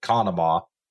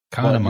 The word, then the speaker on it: Conemaugh: I do want to point out that it's pronounced, as far as I've heard it, Conemaugh. – Conemaugh, you actually pronounce it Conemaugh.